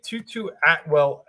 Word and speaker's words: Tutu Atwell: So I'm going to take Tutu Atwell at 3K. Hold on. I can't spell right, but I will get it right in a second. – You said it right.